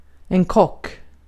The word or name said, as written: kock